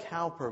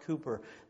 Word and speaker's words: Cowper: It's spelled Cowper, but it's really pronounced Cooper.